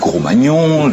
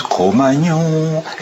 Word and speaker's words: Cro-Magnon: Certains, l'homme du Gros-Magnon, l'homme du Magnon. Alors on dit, l'homme du Cro, l'homme du Ma, l'homme du Gnon, l'homme du Cro-Magnon.